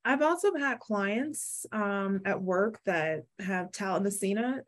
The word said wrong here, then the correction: thalassemia: Tal- am I saying that right? Talamacina. Uh, um, talamacina. Am I? – Oh, thalassemia. Thalassemia. Yeah, thalassemia yes.